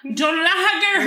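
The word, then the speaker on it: Dún Laoghaire: We're sorry about how Dunleary is spelled. – It's fucking... – It's so Dun difficult. – Lager or hair. – Dun lager.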